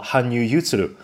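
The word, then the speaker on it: 羽生結弦: 羽生结弦在日文里面读作 Hanyu Yutsuru，所以他的粉丝有的叫牛粉，有的叫柚子粉。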